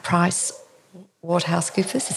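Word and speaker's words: PricewaterhouseCoopers: So James is now a cybersecurity consultant at Price WaterhouseCoopers. Is that how you say it? – Yeah, that's how you say it.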